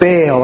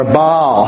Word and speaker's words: Baal: Now, a side note about Baal or Baal, however you want to pronounce it.